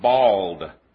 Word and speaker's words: boiled: I did know a brother who thought that the pronunciation was bald. B-A-L-D. That is how some pronounce it. Boiled can be pronounced bald.